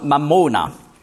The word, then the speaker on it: Mammona: Auf Aramäisch spricht man dann aus Mammona.